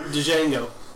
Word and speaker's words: Django: No, that's Django. Django. – The D is silent here.